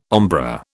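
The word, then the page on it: ombré: British English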